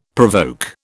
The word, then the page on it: provoke: British English